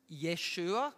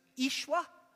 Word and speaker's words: Yeshua: In Hebrew, it's really, people think it's pronounced Yeshua. I'm sure you've heard all that. Some songs even have it in it, Yeshua. Although actually, the way Aramaic people spoke, it was pronounced Ishwa.